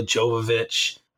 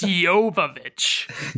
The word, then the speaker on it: Jovovich: Around him, like you said, were like Matthew McConaughey, Ben Affleck, Parker Posey, Mila Jovovich. – Whoa, hold on a minute. Mila Jovovich. It's Jovovich. – Joey Lauren Adams.